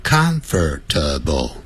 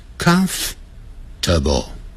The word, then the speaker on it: comfortable: 哎，这个 comfortable 你念的时候不要念成 comfortable，不要念成四个音节，念成三个音节 comfortable，所以老外实际念的时候是 comfortable comfortable，他不是念 comfortable，他是念 comfortable。